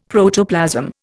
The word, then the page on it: Protoplasm: Indian